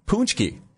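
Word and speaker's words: pączki: How about poonchki? – Oh, yes. It's spelled P-A-C-Z-K-I, right? – Yes.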